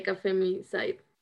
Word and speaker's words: femicide: He was a feminicide. Is that how you say it? Like the guy that kills feminist, or the guy, it is a guy that kills women, just like that. So Femicide. – Yeah, yes. Femicide, yeah.